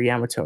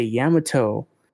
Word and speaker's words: Yamato: the dub for Luffy went to go say Yamato. And she pronounced it like tomato. It was like, it was terrible.